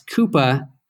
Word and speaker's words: Quuppa: And in case you're wondering, that's Coupa, Q-U-U-P-P-A. So Tom, thanks for joining us. – Thanks. Kudos for getting the pronunciation correct.